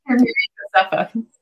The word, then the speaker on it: Josefa: So your your name is Sister Mary Joseph. Hopefully, I'm saying saying it right. Um, Josefa. – What's that? – Sister Mary Josefa.